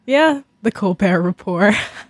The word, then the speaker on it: Colbert: That will include not only snippets of the original Comedy Central shows like The Daily Show and The Colbert Report, good job with the pronunciation. – Well, those are actually limited what they can rebroadcast. – It's French.